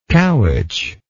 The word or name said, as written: cowitch